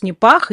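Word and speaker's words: Nipah: Потому что этот вирус, о котором пишут СМИ, вирус НИПАХ или НИПА, некоторые его произносят, но будем говорить НИПАХ, он не новый, известен он с 98 года, а циркулировал, скорее всего, раньше, просто его не замечали до этого.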